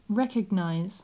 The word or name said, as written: recognize